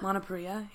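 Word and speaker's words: Lana Parrilla: Is it Lana Parilla? – Lana Parilla, yeah.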